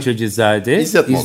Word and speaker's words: Keçecizade İzzet Molla: Keçecizade İzzet Molla.